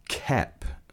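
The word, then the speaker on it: Koepp: And the script was written by David Kep, pronounced. – Yeah. – So there's an O in there, but it's definitely a silent O. So it's David Kep.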